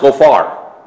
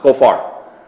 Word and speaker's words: gopher: Here is how it's pronounced, Gophar. Just like we pronounce it, Gophar.